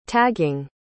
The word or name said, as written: tagging